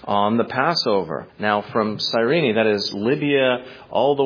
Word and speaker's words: Cyrene: As verse 21 of this chapter tells us, he was from Cyrene, or Cyrene as it's sometimes pronounced, located in modern-day Libya in North Africa.